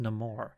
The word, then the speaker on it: Namor: It is Namor. Namor. – Oh. – Or Namor, but okay. Pronounced Namor.